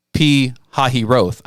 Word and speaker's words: Pi-hahiroth: Pi-hahiroth.